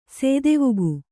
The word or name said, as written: ಸೇದೆವುಗು